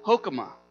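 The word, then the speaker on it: chokmah (חָכְמָה): In the Hebrew language, it is roughly pronounced chokmah. Chokmah. So say that with me.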